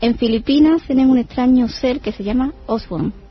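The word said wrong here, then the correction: Aswang: Que yo, como domino varios idiomas, yo te puedo decir que se pronuncia Aswan y ya lo dejamos así...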